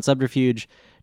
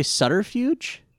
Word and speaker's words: subterfuge: Your text here says, Professor von Subterfuge, which I just noticed, I say subterfuge, you say Sutterfuge? – Yeah. – I guess both are correct. I pronounce the B. – I've always pronounced the B as sort of silent, or you like skip over it real quick.